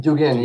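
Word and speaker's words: Dugin (Дугин): I guess, would they broadly look, I mean, I'm thinking of thinkers like Alexander Dugin, I hope I'm pronouncing. – Dugin, yes.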